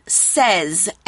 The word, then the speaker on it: says: Now, you should also know that say can have different pronunciations for different spellings. Say is spelled S-A-Y, it's pronounced A. And then we have says, S-A-Y-S, says. So one of them is for I, you, we, they, I say, and then we can say he says.